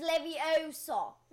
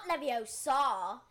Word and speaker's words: Leviosa: And besides, you're saying it wrong. It's Leviosa, not Leviosa.